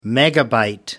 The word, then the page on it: megabyte: American English